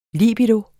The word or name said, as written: libido